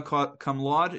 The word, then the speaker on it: cum laude: Ben-Porat graduated, I hope I pronounce all this Latin right, summa cum laude, is that correct?